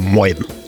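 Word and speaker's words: Moin: «мойн». Произносится оно на выдохе хрипловатым голосом простуженного моржа. Примерно так. «Мойн».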